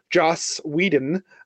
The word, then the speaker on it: Joss Whedon: I mean, which is like that we have to bring up, of course, Zack Snyder and, Josh Whedon. I can never say his name right. Joss Whedon.